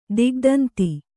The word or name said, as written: ದಿಗ್ದಂತಿ